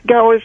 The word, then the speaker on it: Gower: G-O-W-E-R. – Gower. – Spell it for me one more time. – G-O-W-E-R.